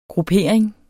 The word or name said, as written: GRUPPERINGER